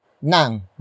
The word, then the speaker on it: ng: Up next we have nang, which is spelled as ng.